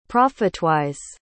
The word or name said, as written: profitwise